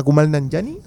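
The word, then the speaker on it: Kumail Nanjiani: A mí, por ejemplo, me llama mucho la atención la mala que le tienen a Taika Waititi y o a, a Kumal Nanjani, así se pronuncia.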